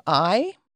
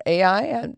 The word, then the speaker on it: Ai: the Battle of Ai, or Ai, different people pronounce it different ways.